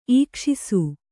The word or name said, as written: ಈಕ್ಷಿಸು